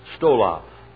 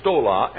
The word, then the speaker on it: stola: Stola. Now, there are two words. One is stola and one is stole. S-T-O-L-A or S-T-O-L long A. Stola, short A. Stole, long A.